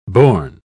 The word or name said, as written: bourn